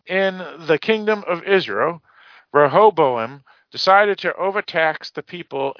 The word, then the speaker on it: Rehoboam: It's pronounced Rehoboam.